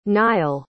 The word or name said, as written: niall